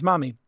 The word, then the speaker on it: Mahmee: And the company is Mommy. And it's phonetically spelled because that's a great way to get a killer domain.